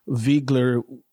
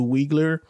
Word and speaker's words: Wiegler: I also like the fact that they pronounce Viegler with its German pronunciation as opposed to Wiegler.